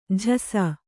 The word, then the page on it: ಜೊಸೆ: jose